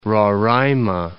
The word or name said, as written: Roraima